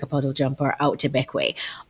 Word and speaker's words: Bequia: It's spelt Bequia, but it's actually pronounced Beckway.